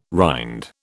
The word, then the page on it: rind: British English